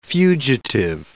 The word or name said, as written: fugitive